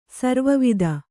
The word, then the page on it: ಸರ್ವಾವಧಿ: sarvāvadhi